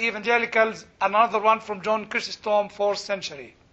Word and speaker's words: Chrysostom: CH. Yes, it sounds like a K. You are close. Chrysostom, yes, that's right.